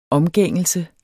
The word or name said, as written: omgængelse